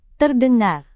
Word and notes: terdengar: indonesian

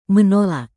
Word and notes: menolak: Indonesian

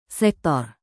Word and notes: sektor: Indonesian

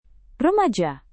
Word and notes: remaja: indonesian

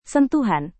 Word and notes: sentuhan: indonesian

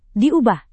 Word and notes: diubah: Indonesian

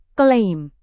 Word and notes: klaim: indonesian

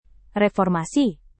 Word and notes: reformasi: indonesian

